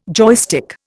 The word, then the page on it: joystick: Indian